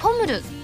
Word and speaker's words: ポムル: ったと思いますので再度「はらまる」で募集をかけてみるというのはいかがでしょうか個人的にはアルファベットを拾った、えー、 POML えー、ポムルと読んでみるというのを思いつきましたアクセントはハラミーと同じ伸ばすんですね